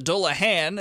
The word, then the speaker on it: Dullahan: But the Dullahan, as I am now correctly pronouncing, now that I've heard you say it, where did that one come from? – So I knew I needed a good monster.